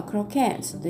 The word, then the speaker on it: croquettes: これコロッケはですね、英語ではクロケッツというふうに言いますね。フランス語から来てると思うんですけれども、クロケッツの K のところにアクセントがつきます。